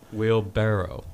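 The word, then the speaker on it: wheelbarrow: Wheel barrel. – It's barrow. – It's not barrel? – Barrow.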